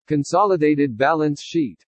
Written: CONSOLIDATED BALANCE SHEET

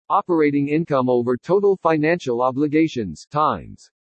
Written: OPERATING INCOME OVER TOTAL FINANCIAL OBLIGATIONS TIMES

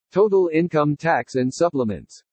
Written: TOTAL INCOME TAX AND SUPPLEMENTS

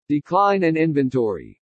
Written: DECLINE AND INVENTORY

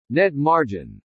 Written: NET MARGIN

